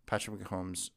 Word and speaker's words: Patrick Mahomes: Patrick Mahomes longest completion over 39.5 yards.